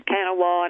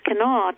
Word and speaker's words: Kanawha: There, I'm showing my new Just like Kanawha County, remember the outsiders talked about it as Kanawha and everything else, but it's Kanawha to West Virginians.